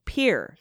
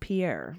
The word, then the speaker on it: Pierre: We pronounce the capital South Dakota with one syllable, Pierre. – Thank you. Like the verb, yeah, not the French you. name. – Oh, not Pierre.